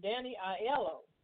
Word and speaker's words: Danny Aiello: We lost a great one, Danny Aiello.